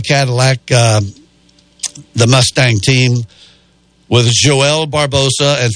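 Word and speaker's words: João: And I put in J-O-A-O, Barbosa, and every single translation was Joel. Like, it's got an L on there, but the L's not there.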